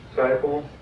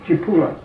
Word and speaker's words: Cipolla: Is that how you pronounce the word, Saipo? – The right way to pronounce it is Cipolla. – Cipolla. – That's Italian.